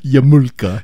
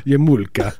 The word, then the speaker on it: yarmulke: Yarmulke. – Yarmulke.